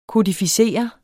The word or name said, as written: kodificere